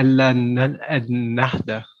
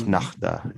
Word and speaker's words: Ennahda: Ennahda. Am I pronouncing that right? – Yeah, Ennahda. – Of Ennahda, yes. I've done several episodes on Tunisia, and I've just never gotten the name of that political party correctly pronounced. – It's the H in the middle.